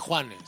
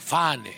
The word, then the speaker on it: juane: Perdón, no se dice Juane, se dice Fane.